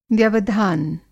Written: व्यवधान

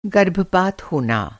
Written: गर्भपात

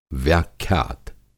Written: व्याख्यात

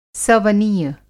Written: सवनीय

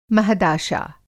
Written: महदाशा

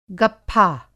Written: गप्फा

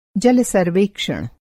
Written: जल-सर्वेक्षण

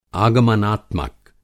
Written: आगमनात्मक

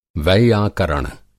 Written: वैयाकरण

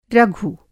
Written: रघु

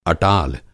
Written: अटाल

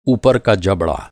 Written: जबड़ा

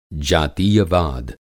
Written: जातीयवाद

